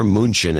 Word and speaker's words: München: Andrew Wimster in Munich, Deutschland, or München as it should be pronounced, 5272.